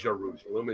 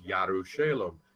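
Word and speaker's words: Yerushalem (ירושלים): So, but there is no J, so therefore it cannot. – It's pronounced totally differently. – Correct. It's right. Not Jerusalem, it's Yerushalem.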